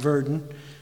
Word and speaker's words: Werden: He said, "If you were to travel to Werden, Germany," probably pronounced Verden, "Werden, Germany, high up on the front of the church there, you would notice the figure of a lamb engraved there.